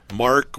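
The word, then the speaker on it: Marc: And that is Mark with a C.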